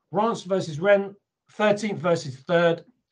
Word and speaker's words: Reims: But it's actually pronounced Reims with no M.